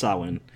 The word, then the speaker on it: Samhain: It's spelled Samhain, but it's pronounced Samhain. Because they do it right in Halloween 3.